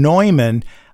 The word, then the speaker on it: Neumann: Now, we pronounce his name St. John Neumann many times, which is the traditional pronunciation from where he was in Bohemia, which is now the Czech Republic.